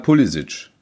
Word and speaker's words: Pulisic: Auch da sagt der Spieler, klar, ich habe kroatische, glaube ich, Vorfahren und die sagen halt Pulisic.